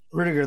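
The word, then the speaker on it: Ruettiger: Rudiger? – Rudiger, that's it, man.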